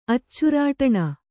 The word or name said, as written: ಅಚ್ಚುರಾಟಣ